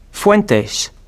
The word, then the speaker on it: Fuentes: Fuentes. – How do you spell that? – F-U-E-N-T-E-S.